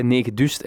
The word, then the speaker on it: Negedust: Aan de telefoon heb ik Thomas Bijls, hij is zaakvoerder bij Negedust. En Negedust, dat is op zijn Gens uitgesproken de postcode van Gent natuurlijk, 9000.